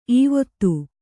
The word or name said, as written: ಈವೊತ್ತು